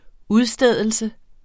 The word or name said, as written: udstedelse